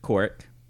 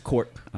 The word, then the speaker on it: Korok: So the locals pronounce it Korok. – Oh. – Or just Kork.